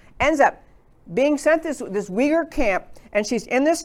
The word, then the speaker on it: Uyghur: It starts with a U, but it's pronounced Uyghur.